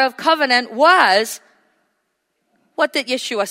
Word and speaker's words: Yeshua: Jesus in English, of course, but Yeshua in his original pronunciation, which means the Savior.